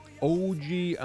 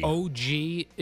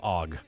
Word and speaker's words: OG: OG Anunoby. – OG is his actual first name? – Yes, you don't call him Og.